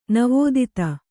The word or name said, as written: ನಿವೇದಿತ